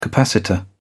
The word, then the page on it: capacitor: British English